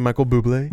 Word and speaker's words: Bublé: Michael Bublé is swinging through here. – Hey, there you go. – I feel like you have to always say his name, Michael Bublé. – Bublé.